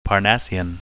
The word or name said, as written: parnassian